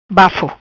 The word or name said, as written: Bafo